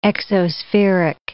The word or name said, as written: exospheric